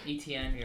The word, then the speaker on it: ETN: NTA? – ETN.